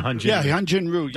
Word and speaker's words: Hyunjin Ryu: I did put up Hyunjin Ryu. Is that how you pronounce the name? – Yeah, Hyunjin Ryu.